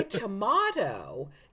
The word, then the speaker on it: tomato: Although, oddly enough, when you say tomato, you want to pronounce the T more. You don't want to flap.